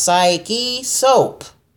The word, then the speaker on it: Psyche Soap: Yeah, it's pronounced Psyche Soap. How hard is that? You could say either one of them two damn words, but when you put it together, you just fuck them up.